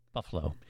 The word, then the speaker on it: Buffalo: It's like the city of Buffalo is actually pronounced with only two syllables, you know, Buffalo, if you're from there.